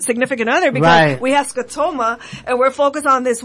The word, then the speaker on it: scotoma: By the way, you've been saying it right this whole time. I just looked it up. So now you can say it with confidence. – Okay, scotoma, right.